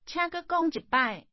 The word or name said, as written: say it again